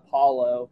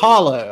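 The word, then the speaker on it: Paolo: How do you say it? I, have heard a lot of people say uh, Paulo. I believe. – Paulo. Okay.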